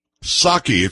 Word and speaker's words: Psaki: Psaki. It's not Psaki. It was just Psaki. You didn't pronounce the P.